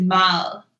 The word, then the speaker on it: mad: M-E-G-E-T is pronounced mel. – What? – And M-A-D is mal.